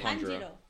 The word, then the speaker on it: Tanjiro: Tanjiro.